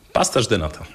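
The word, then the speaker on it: pastéis de nata: Pasterz de nata, dokładnie. Mhm. – Wszystkie S na końcu w języku portugalskim, tak, wymawiamy tak, jak tak. Esz.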